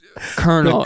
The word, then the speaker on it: Colonel: It's pronounced Colonel. It's pronounced Colonel, and it's the highest rank.